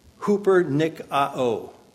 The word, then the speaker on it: hupernikao: It is the word that is spelled like this in English, translated from the Greek. H-U-P-E-R-N-I-K-A-O. Hupernikao.